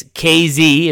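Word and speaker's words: Kazee: I said Kazi on Monday. I know that Jeffrey Benedict made that mistake. He's the one that told me it's KZ is how you say the last name.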